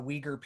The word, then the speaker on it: Uyghur: But he is absolutely silent when we talk about the Uyghur people. And I'm so sorry if I'm mispronouncing that. I spent the last half hour verifying if that was the actual way to pronounce it, but the Uyghur people of China,